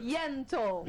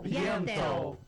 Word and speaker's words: Yentl: Ó, a querida Alessandra Maestrini, ela tá em peça, ela tem peça, que é Ent. Ent in Concert. – Yentl. – Yentl. – Perdão, eu vou fazer com pronúncia. Yentl in Concert.